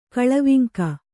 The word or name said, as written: ಕೊಳವಂಕೆ